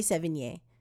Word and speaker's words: Sevigny: In the episode, in this episode, Chloe Sevigny, Sevigny's name is mispronounced. I, I just fumbled her name again. Chloe Sevigny. Name is mispronounced.